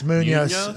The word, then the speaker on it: Munhoz: That's how it's spelled. – Yeah, it's Munhoz.